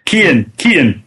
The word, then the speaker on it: Cian: Sean, I believe. – Kian. – Kian, is that how you say it? – Okay. Yeah, it's an Irish name. – Yeah, C-I-A-N.